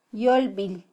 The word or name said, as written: yolbil